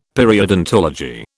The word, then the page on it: periodontology: British English